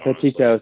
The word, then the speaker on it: Petito: For a more somber note, we're going to talk a little bit about the Gabby Petitgo. I don't know how to say her last name. One of that case that's going on. – That's what I thought. – Petito, thank you.